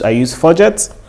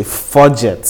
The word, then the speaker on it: Fudget: But the one I use Fudget. Let me pronounce it so you know what I said and what I didn't say. Fudget. So it's like funds and budgets, right? That's what it is, Fudget.